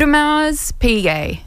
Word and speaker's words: Audemars Piguet: Audemars Piguet.